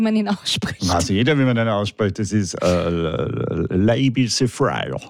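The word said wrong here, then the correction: Labi Siffre: Ich glaube, so heißt er nicht. – Nein, er heißt Labi Siffer. – Na, siehst du. Das ist richtig ausgesprochen.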